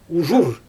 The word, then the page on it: uʒur: Xyzyl